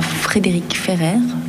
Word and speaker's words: Frédéric Ferrer: Et on va tout de suite passer le micro à Frédéric Ferrer.